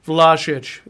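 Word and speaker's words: Vlašić: That's for our Croatian friends who actually watched and corrected our pronunciation. Vlahovic, there you go.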